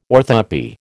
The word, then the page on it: orthoepy: American English